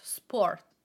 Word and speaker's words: sport: Today we are talking about sport. The way that we pronounce this word, the Italian way, is sport. Sport. It is an O that is more open. Sport.